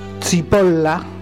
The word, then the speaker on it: Cipolla: Bueno, el autor, Carlo Maria Zipolla.